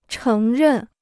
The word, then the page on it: 承认: chéng rèn